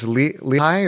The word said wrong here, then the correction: Leahi: or is that the correct pronunciation? – Well, that's the Hawaiian word. It's Leahi, and that's Diamond Head.